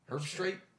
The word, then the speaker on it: Herbstreit: It's H-E-R-B-S-T-R-E-I-T. – E-R-T. – And you said straight? Herbstreit? – Herbstreit. Right now, I did say that.